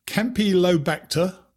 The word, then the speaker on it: Campylobacter: Campylobacter, which may have been said incredibly wrongly. E. coli. – No, you said it right.